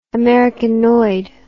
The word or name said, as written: americanoid